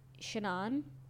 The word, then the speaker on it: Senan: Maybe it was, his name is Sina. Well, I don't know how to pronounce it. Sinan, S-E-N-A-N. Sinan.